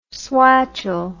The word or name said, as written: swatchel